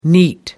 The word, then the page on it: neat: American English